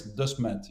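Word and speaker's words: Desmet: Everybody's struggling with the pronunciation, but it's Dismet, yes.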